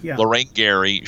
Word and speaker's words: Lorraine Gary: Lorraine Gary.